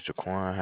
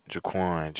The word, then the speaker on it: Jaquan: Jaquan, J-A-Q-U-A-N. – And you pronounce it? – Jaquan. – Oh, Jake is your first name? – Mm-mm, it's Jaquan, J-A-Q-U-A-N. – J-A-Q-U-A-N.